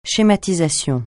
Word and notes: schématisation: francais

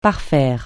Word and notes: parfaire: francais

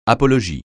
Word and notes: apologie: francais

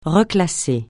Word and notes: reclasser: francais

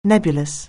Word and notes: nebulous: anglais

